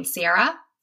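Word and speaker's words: Ciara: One of those is Level Up by, I never know how you say her name, Sierra? – Yeah. – Okay. – That's how I say it.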